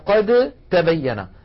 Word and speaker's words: قد تبين: كذلك قوله تعالى قد تبين، التقت هنا الدال والتاء وهما من المخرج نفسه، فنحول الدال إلى تاء ثم ندغم التاء في التاء، فيصير النطق بتاء مشددة ولا يبقى للدال أثر. هكذا قد تبين الرشد من الغي، قد تاء ولم يعد هناك دال، ولكن لاحظوا يا إخوة هذا في حال الوصل فقط، أما لو وقفنا فنقول قد، قد بالدال الواضحة.